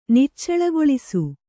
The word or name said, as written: ನಿಚ್ಚಳಗೊಳಿಸು